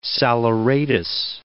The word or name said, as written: saleratus